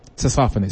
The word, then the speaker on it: saxophonist: Saxophonist, is that how you say it? Saxophonist, I think that's right. – No, I say saxophonist. – Saxophonist, okay.